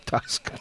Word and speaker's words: Tusk: Tusk. Rhymes with Dean Rusk.